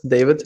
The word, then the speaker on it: David: So I'm originally from Germany, and bringing this up because you pronounce my first name German. Everybody says David, you know, yep. – these days, and back in Germany, it's David.